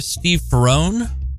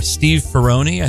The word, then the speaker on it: Steve Ferrone: You can hear all songs tonight featuring the legendary drummer Steve Ferrone, or Steve Ferrone, I think, heard it pronounced both ways.